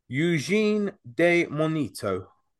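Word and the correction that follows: Eugénie de Montijo: Let's try it at Eugenie de Montijo. – There you go. See, butchered it.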